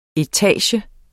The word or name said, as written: etage